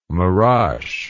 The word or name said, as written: maras